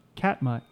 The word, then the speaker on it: Katmai: No, it's K-A-T. – Yeah. – M-A-I. – Yeah, that's not. How did you pronounce it? – Katamiya, Katama. – No, Katmai. – Okay, Katmai.